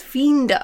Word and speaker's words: fiender: But actually, you corrected it and said, actually, it's fiender. Is that how it's pronounced? – Fiender, yes. – So I suppose that's like the English fiend, which would suggest...